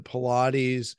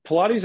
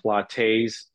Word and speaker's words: Pilates: What my vote for Pilates has a weird connotation. For one, people pronounce it wrong a lot. Pilates, Pilate. They think it's from the Bible with Pontius Pilate.